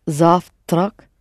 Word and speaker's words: завтра: Sorry, когда завтрак завтра? – Mm-hmm. Only accent on the same «за».